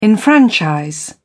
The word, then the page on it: enfranchise: British English